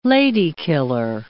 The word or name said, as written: lady-killer